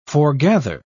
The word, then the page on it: forgather: British English